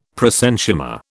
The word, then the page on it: prosenchyma: British English